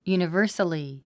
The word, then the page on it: universally: jùnəvə́rsəli; ユニバァサリィ